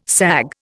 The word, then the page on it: sag: Indian